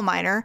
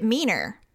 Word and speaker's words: Miner: And so when Mackenzie started working there, her last name is Miner, like a coal miner. Mm-hmm. But he pronounced it Meaner because... I guess for French, he thought that was how you pronounced it. She goes, no, it's Miner.